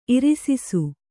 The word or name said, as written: ಇರಿಸಿಸು